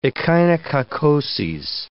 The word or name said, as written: echinococcoses